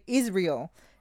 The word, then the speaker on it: Israel: So I guess in English you say Israel, and now I'm okay saying it.